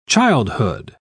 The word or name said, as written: childhood